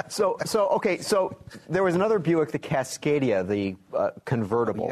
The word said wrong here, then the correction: Cascada: Did I say it correctly? Cascada.